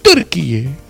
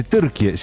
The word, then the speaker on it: Turquía: Y se escribe TURKIYE. Me investigué y se dice Turquía. – Se dice Turquía, sí, así salen los álbumes de fútbol, por ejemplo.